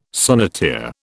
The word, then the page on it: sonneteer: British English